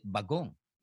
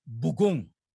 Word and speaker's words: bagoong: As an Ilocano, we don't call it bagoong. Mm. It's bugong. – Bugong. Two syllables.